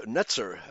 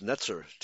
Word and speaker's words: Netzer: Nezer, or should, Netzer. I think it should be pronounced Netzer. TS is the actual pronunciation of the Hebrew Z.